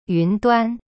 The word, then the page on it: 云端: yun duan